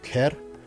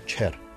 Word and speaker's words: ker: Arlije kažu ker, a gurbeti kažu čer.